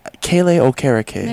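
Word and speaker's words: Kele Okereke: singer Kaylee Okereke. – One more time? – Kaylee Okereke. – There you go. – I believe is how you pronounce the gentleman's name.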